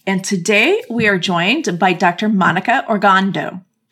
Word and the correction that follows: Ogando: Ogando, no R in it, just Ogando. – Ogando, thank you. Sometimes the R just slipped in.